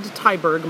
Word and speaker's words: Tyberg: You can find it at David Tyberg. My last name is spelled T-Y-B-E-R-G.